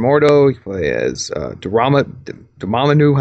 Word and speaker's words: Dormammu: Yeah, but it's like three syllables, though. Dormammu.